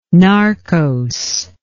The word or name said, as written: narcose